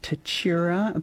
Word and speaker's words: Tatura: I've had some texts in on Tatura. Apparently I'm saying it correctly.